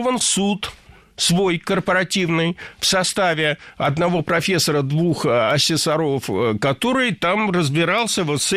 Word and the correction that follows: ассессоров: Ассессоров – неверно. Правильно – ассессоров. Существительное «ассессор» имеет неподвижное ударение на втором слоге. Ну а раз оно неподвижное, значит, мы его не двигаем при изменении слова. Ассессоров – ассессорами – ассессором.